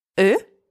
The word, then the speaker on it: eux: Eux. – Them. Masculine, and careful here, don't pronounce the final X.